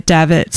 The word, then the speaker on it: Davitt: Davitt? – Yeah, the Davitt. – How do you spell that? – D-A-V-I-T-T.